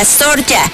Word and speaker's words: Zorya: Si bien juega en la ciudad rusa de Abramovka, utiliza la ortografía rusa para nombrarlo Zarya, contra el ucraniano que se pronuncia Zorya, y reclama la historia del club original.